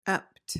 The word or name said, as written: ept